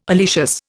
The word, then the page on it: oleaceous: Indian